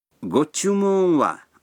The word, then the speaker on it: ご注文は: Го-чумун-ва означает «что бы вы хотели».